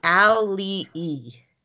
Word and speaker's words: Auli'i: So I wrote down how to say the star's name phonetically. It's Auli'i. – Okay. – Spelled A-U-L-I-I. – Wow, that's hard. Is there an apostrophe between the eyes? – Yes, there's an apostrophe between the eyes.